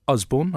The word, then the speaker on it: Usborne: Well, I've got a publishing great with me today, Peter Usborne.